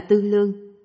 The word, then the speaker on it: tư lượng: Viết là tư lượng, nhưng đọc là tư lương.